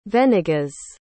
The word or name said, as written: venegas